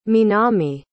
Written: minami

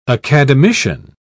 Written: academician

